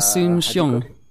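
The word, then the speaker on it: Soon-Shiong: Is it Dr. Soon Shiong? – Yeah.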